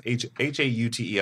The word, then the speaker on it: haute: You know, I've been a fan of your take on what a how do you pronounce haute? I'm not sophisticated enough. Is it haute? Do you have to be French enough to say? – No, you don't. You said it beautifully.